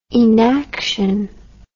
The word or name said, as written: enaction